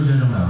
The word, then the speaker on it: 学生: 三音节，学生，学生，双，大学生，小学生，中学生，留学生，留学生来了。 – เรี等差。等差。留学生，大家看，很有意思。学生是一个词，这个词当中有声调吗？有声调吗？学生，学生，饱饱散散，我们叫什么？轻声啊，学生。